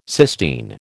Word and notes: Sistine: American English

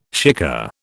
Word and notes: shikar: British English

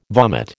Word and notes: vomit: American English

